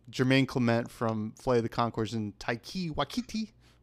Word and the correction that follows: Taika Waititi: whatever. And Taika Waititi.